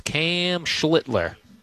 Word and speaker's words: Cam Schlittler: He'll pitch tonight against Cam Schlittler. – Good job on the pronunciation.